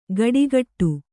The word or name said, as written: ಗಡಿಗಟ್ಟು